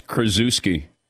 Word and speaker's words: Krzyzewski: It's Krzyzewski. Paulie, can you do it? – Krzyzewski, bang.